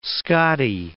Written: Scottie

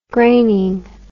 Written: graining

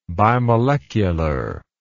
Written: bimolecularly

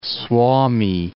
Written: Suomi

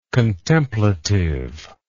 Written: contemplativeness

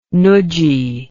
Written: nudgy

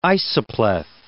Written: isopleth

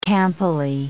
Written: campily

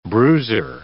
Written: bruiser

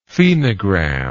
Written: phenogram

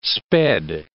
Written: sped

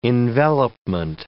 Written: envelopment

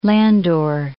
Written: Landor

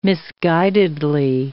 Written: misguidedly

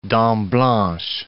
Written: Dent Blanche

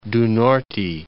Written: Paraiba do Norte

